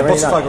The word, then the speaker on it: Botafogo: Yeah, Botafogo really in Rio.